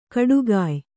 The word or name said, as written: ಖಡಗಾಯಿ